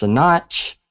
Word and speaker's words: Sinach: Waymaker by, I want to say it's pronounced Sinach.